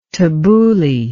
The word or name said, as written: tabooli